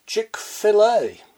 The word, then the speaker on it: Chick-fil-A: In that state of ignorance, I mispronounced the name as Chick-fil-A. Short A. A mighty host of listeners emailed in to tell me that it's pronounced Chick-fil-A. As in, fillet.